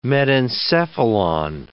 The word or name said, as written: metencephalon